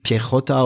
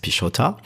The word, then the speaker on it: Piechotta: Ich wollte unbedingt Pichotta oder Pichotta sagen, aber das heißt Pichotta.